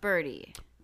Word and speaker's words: Bertie: B-E-R-T-I-E. – Oh, it's still Birdie. – Birdie. – That's pronounced Birdie.